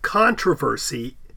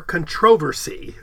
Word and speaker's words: controversy: A lot of times it's listed as having the British pronouncing it that middle syllable way, or, you know, controversy. – Controversy? – Yeah, controversy or whatever. Saying it comes from the influence of the word's Latin origin, laboratorium, etc. However, a lot of British say the traditional British pronunciation is, as we pronounce it, controversy.